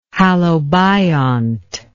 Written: halobiont

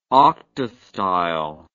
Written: octastyle

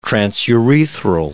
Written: transurethral

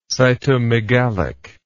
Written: cytomegalic

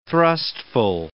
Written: thrustful

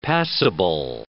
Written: passible

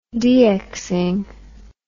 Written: DXing

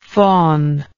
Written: fawnlike

